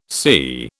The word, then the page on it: CIE: British English